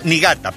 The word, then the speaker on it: Niigata: Niigata, perdón. Se escribe con doble I. N-I-I-G-A-T-A.